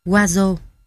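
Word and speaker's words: hózhó: Khái niệm của người Navajo về Hozo đọc là Wazo, có nghĩa là sự hài hòa, sự tĩnh tại của tâm trí.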